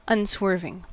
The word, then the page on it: unswerving: American English